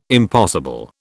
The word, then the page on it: impossible: British English